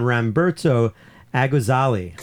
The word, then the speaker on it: Ramberto Agozalie: Finally, an album called Gagu by Lucas Abela, Ruli Shabara, and Ramberto Agozalie. – Three... – Did I say that right? You gave me a nod of approval.